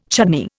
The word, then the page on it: chutney: Indian